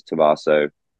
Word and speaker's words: Tommaso: I go by Dom Tomato on most social platforms because my last name is Tommaso, rhymes with tomato, easiest way to do it.